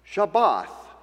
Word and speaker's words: Shabbat: Shabbat is the way its ancient predecessor is essentially pronounced.